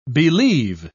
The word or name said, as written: believe